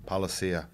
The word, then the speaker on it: Palasia: Palasia.